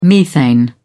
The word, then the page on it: methane: British English